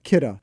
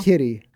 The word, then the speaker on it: Kidde: Kidde. Not Kitty.